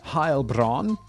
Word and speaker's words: Heilbronn: I'm assuming Heilbronn is somewhere in Germany because I looked up the pronunciation and it said German. Heilbronn.